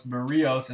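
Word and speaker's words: Berríos: So when I was listening to the broadcast, Dan was saying Berrios, and then he went to this explanation that Berrios had told everyone that that's how you say it and that's how you go to it.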